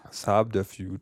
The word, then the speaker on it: subterfuge: Ich dachte jetzt, das B ist nur halb silent. – Ja, also B ist gar nicht silent. – Subterfuge.